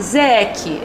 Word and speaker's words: ZEC: Eu queria começar esse próximo bloco, agora esse segundo bloco, Gabriel, falando sobre a ZEC. É assim que se pronuncia? Isso, exatamente.